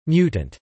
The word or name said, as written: mutant